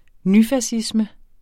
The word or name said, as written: nyfascisme